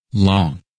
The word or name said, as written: Long